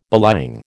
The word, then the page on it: belying: American English